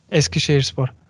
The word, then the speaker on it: Eskişehirspor: Wie spricht man den Namen richtig aus? Eskişehirspor. Ja, genau so.